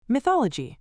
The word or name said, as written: mythology